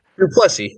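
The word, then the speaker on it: Du Plessis: And Strickland lost the fight to how do you say it? Duplessis? – Duplessis, yeah, he got Duplessis. – It. – Duplessis, okay. All right, Mike was trying to convince me it was Duplessis.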